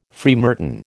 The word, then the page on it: freemartin: American English